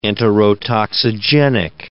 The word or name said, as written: enterotoxigenic